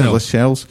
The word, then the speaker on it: Lascelles: Lassell, you know, is it Lassell? Is that how you pronounce it? Lascelles.